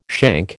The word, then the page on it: shank: American English